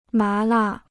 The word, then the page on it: 麻辣: má là